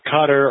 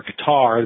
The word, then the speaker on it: Qatar: a Qatar or Qatar, depending on how you want to pronounce the country, and Saudi Arabia in particular.